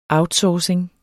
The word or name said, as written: outsourcing